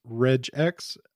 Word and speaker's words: regex: And before we move forward, I got to clear the air and I just got to ask, is it regex or regex? – I have in my travels always heard it pronounced regex, but I'm not really a big fighter on the GIF GIF thing either. I've always called it a GIF, so whatever. So I'm guessing my soft G is following through here with regex.